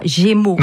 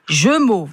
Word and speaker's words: Gémeaux: On ne prononce pas Gémeaux, on prononce Jeumeau, voilà.